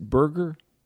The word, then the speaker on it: Berger: Is it Berger? – Yes, it is. Just like hamburger, just spelt a little differently.